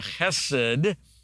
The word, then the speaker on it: chesed: It's got that throaty chesed. It's spelled C-H-E-S-E-D, but if you spell it with a K, it'll help you pronounce it chesed.